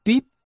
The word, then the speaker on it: tuýp: Từ chữ tuýp là cái ống, phát âm là tuýp.